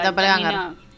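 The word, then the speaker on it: Doppelgänger: Doppelganger, o no sé si lo estoy pronunciando bien. – Sí, Doppelganger.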